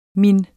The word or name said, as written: min